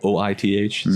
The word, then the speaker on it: Oith: It's spelled OITH, so it's Oith.